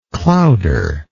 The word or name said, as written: clowder